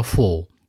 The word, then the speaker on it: fool: 大家注意一下啊，有一个词的发音就是 foolish 的词根叫 fool，就是有点圆口的。而还有一个词吃饱了叫 full，不怎么张口的。它们俩区别在于呢，发音很像。